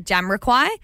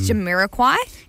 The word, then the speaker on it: Jamiroquai: I keep saying Jamiroquai, but it's Jamiroquai.